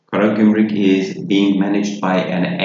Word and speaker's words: Karagümrük: Karagümrük.